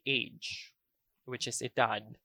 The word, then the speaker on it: edad: So instead of saying 24 años, we can also say edad 24. Edad 24. So edad literally means age.